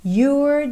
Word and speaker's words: you're: And if I know you, you're doing that thing. You're doing. Atenção que o é não é pronunciado. Então, você tem you're com a língua enrolada, ok?